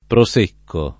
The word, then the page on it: Prosecco: lingua italiana